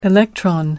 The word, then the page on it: electron: American English